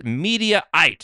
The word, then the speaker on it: Mediaite: And this is according to Mediate, or how do we say this? Mediaite, excuse me.